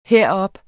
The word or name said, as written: herop